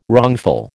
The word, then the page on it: wrongful: American English